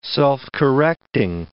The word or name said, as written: self-correcting